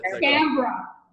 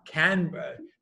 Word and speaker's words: Canberra: You've been talking to these government officials in I can't pronounce the Canberra. – Canberra.